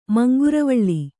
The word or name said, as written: ಮಂಗರವಳ್ಳಿ